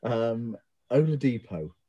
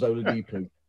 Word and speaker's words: Oladipo: I always end up adding extra O, so it becomes Oladipo.